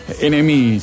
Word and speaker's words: NMI: NMI se escribe, si lo buscan, la N, la M y la E, porque como en inglés, NMI.